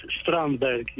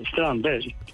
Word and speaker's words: Strandberg: bastante impronunciable, Strandberg, yo soy sueco. Ah, sueco. Y si quiere te lo canto en sueco, pero Strandberg.